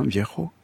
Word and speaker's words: viejo: Le viejo, son grand-père, je le dis bien, hein, viejo, était mort et lui avait laissé ses deux petites maisons de tortilla flat.